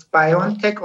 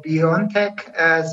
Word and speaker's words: BioNTech: Yeah, you can pronounce it. It's BioNTech or BioNTech.